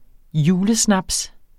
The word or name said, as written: julesnaps